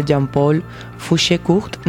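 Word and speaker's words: Jean-Paul Fouchécourt: El otro va a ser Jean-Paul Fouché-Court.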